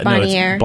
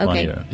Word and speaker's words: Bonnier: Am I saying that right? – Corp. Yeah, Bonnier. – Okay. Bonnier.